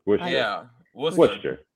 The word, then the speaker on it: Worcester: There's it's almost like you're saying like Wooster, kind of like Worcester. – Worcester. – Yeah. – Yeah. And I was like Wooster. Yeah. Something like that. I'm pretty positive. I'm like 99% that's how you say it.